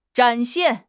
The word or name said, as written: 展现